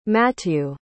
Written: mathieu

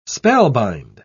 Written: spellbind

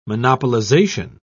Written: monopolization

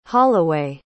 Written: holloway

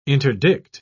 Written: interdict

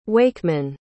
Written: Wakeman

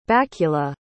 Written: bakula

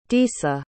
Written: disa

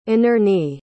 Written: inerney

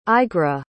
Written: eigra

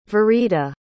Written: verita

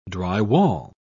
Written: drywall

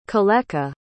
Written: coleka

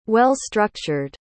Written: well-structured

